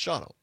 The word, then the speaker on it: Shawano: Jim Albo up there in Shawano, or as they say locally, Shawano. No, they don't. That's how it's spelled. It looks like Shawano, but it's pronounced Shawano.